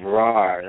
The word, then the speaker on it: Rarh: And she's talking about the ancient civilization of Rar, that's spelled R-A-R-H,